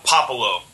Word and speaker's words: Puopolo: Yeah, it's Puopolo. – Puopolo. – So, yeah, nope, not Pope or Poop or anything like that.